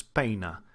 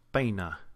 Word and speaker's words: peina: When you're talking about another language, when you're talking about Spanish as an example, when you have a diphthong, and there's really only a few of them, it's not nearly as much of a blending of the vowel sounds. You really hear those vowel sounds distinctly. An example might be the word for comb, like as in he combs his hair or she combs her hair, which is peina, peina.